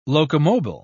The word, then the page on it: locomobile: British English